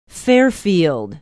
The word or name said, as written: Fairfield